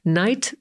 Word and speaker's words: knight: Knight to rycerz. To słowo wymawiamy tak samo jak noc, mimo że na początku jeszcze jest dodatkowa litera K.